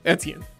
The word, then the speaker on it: Etienne: Etienne, there you go.